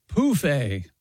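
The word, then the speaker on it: pouf: Anyway, Europeans of a certain age use the French word pronounced poufé for an Ottoman, phonetically spelled P-O-O-F hyphen A.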